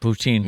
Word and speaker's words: poutine: Poutine, right? – Yep, poutine.